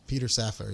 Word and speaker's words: Safar: So there was a good quote I found by, I'm sure I'm going to say this wrong, Dr. Safar. – Peter Safar. Yeah. – Safar. Okay.